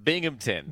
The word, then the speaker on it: Binghamton: But um, uh, how do you pronounce it again? – Binghamton. Binghamton.